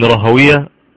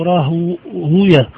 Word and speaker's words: راهويه: في بالنسبة لحضرتك قلت في البداية إسحاق بن راهوية،